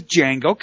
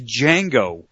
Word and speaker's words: Django: Not the Django. Come on, it's Django. The D is silent.